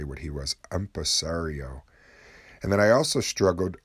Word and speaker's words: empresario: And also earlier I struggled with the word empresario, I believe is is how you say it.